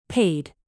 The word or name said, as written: paid